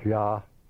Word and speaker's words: Yah: Pronounced Yah, or J-H-Y-A, this name signifies he is and can be made to correspond to I am, just as Jehovah corresponds.